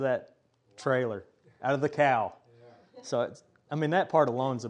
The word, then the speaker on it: KOW: And we also take something called the cow from here at the church. How many of you have heard of the cow before? Somebody, okay. It's spelled K-O-W, and it stands for Kitchen on Wheels.